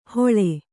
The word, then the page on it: ಹೊಳೆ: hoḷe